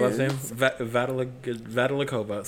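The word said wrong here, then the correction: Zamolodchikova: Katrina Petrovna Zamolachikova.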